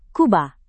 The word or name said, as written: Kuba